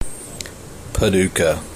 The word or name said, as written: Paducah